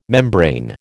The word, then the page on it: membrane: American English